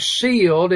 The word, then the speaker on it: cieled: The word sealed is spelled C-I-E-L-E-D.